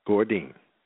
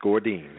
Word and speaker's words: Gourdin: Gourdin. – All right. – It's Gourdin.